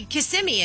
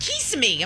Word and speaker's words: Kissimmee: She's the one who's confused me, and I don't know how to say Kissimmee anymore. Yeah, yeah. Did I just say it right? – You said it right. – Yeah, because she's like, Kissimmee. I'm like, that's not how you say it.